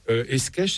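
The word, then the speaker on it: Escaich: Vous parlez du titulaire des orgues, un des organistes, les compositeurs les plus extraordinaires, il s'agit de Thierry Esquèche, c'est comme ça qu'on prononce.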